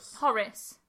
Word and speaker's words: Horace: I can't say the name. Horace, is that how you say it? Horace. – Horace.